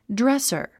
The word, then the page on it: dresser: drésər; ドレッサァ